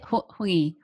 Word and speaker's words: hongi: How do you say hongi? Hongi. – Hongi. – Hongi. – Hongi. Yeah, like, can you say, so. I'm a great singer. So the singa, the nga sound is a hongi.